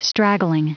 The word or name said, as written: straggling